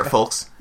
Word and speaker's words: folks: He knows how to use a chair, folks. That's folks? – That's the moral of the story. – Did you just pronounce the L in folks? – That's what you're supposed to do.